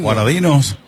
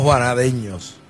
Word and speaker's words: juanadinos: Bueno, pues, alcalde, entonces, ¿cómo se dice a la gente de Juana Díaz, juanadeños? – Juanadinos y juanadinas. – ¿Juanadinos o juanadeños? – No, juanadinos y juanadinas. – No juanadeños. – No, no. Juanadinos y juanadinas.